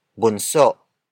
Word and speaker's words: bunso: That focus word is bunso. Bunso. Bunso. Bunso is spelled as B-U-N-S-O.